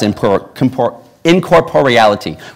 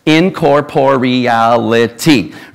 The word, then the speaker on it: incorporeality: So I counted them last night, there are 7 syllables. Incorporeality, right? So let's say it together. Incorporeality. Incorporeality. Whew, that's a big one, right?